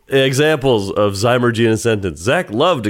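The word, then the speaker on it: Zymurgy: Zymergy.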